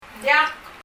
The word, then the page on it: ng diak: ŋ ðjʌk